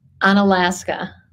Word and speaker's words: Onalaska: Onalaska. – Onalaska.